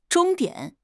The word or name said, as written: 终点